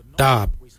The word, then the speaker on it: dhaab: Today we will look at, for knife we say darb. Darb is spelled D-H-A-A-B. It is a traditional knife, a darb.